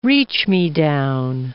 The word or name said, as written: reach-me-down